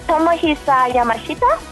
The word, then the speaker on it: Tomohisa Yamashita: He is Tomohisa Yamashita, a singer, actor.